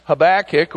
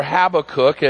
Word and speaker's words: Habakkuk: Habakkuk, or Habakkuk, as depending on how you say it, is prophesying, is a prophet to those two southern tribes of Judah.